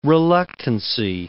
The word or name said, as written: reluctancy